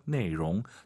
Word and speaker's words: 内容: 내용. 자, 발음하실 때 내용, L 발음 안 돼요. 내용. 롱 많아서 내용 그래요.